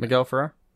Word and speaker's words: Miguel Ferrer: Miguel Ferrer.